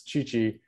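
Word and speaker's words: Tite: a guy called it's spelled TT, but it's Chichi, he was saying that Neymar is going to be relied on as the playmaker in the upcoming World Cup.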